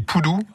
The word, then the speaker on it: pudu: Des poudous, un des plus petits cervidés au monde. – On dit poudou, mais ça s'écrit PUDU, hein, PUDU. Ouais, c'est ça, PUDU. Et ça se dit bien poudou.